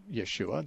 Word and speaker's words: Yeshua: But when the disciples wrote the name in Greek, the Greek language had no SH sound. So the Greeks couldn't pronounce it Yeshua. They couldn't pronounce SH.